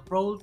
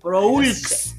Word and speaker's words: Proulx: ¿cómo se pronuncia su apellido? Proul, bueno, Proulx, se escribe Proulx.